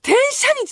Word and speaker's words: 天赦日: 日本の暦でね、その縁起がいいとされてる、これなんて読むんですか？天赦日って読むの？天赦日って読むんや、この字。初めて読んだ。書いてあったけど、カレンダーにずっと。天赦日と、一粒万枚日か。が、重なる、もう、超いい大吉日らしいんですよ。 111 が揃って、天赦日が、と、一粒万枚日。